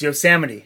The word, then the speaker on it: Yosemite: It's Yosemite.